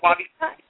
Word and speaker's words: Wabi Sabi: Yes, okay, so Wabi Sabi. – It's not coming out till January 2012, but it's called Wabi Sabi Love, Finding Perfect Love in Imperfect Relationships.